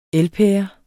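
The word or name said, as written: elpære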